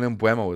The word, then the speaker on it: Mbeumo: Brian Mbeumo is the current problem for most comms. It's not Mbeumo, Mbeumo.